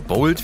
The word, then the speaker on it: Bolt: Und Döneraroma wurde entwickelt von englischen Wissenschaftlern und diesem Fahrunternehmen Bolt. – Ja. – Oder Bolt, wie es ausgesprochen wird.